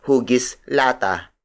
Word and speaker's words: hugis lata: A modern variation is hugis lata. Hugis lata. Hugis lata. Hugis lata is spelled as H-U-G-I-S hyphen L-A-T-A.